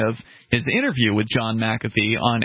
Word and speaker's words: McAfee: Apparently that's how it's pronounced, McAfee.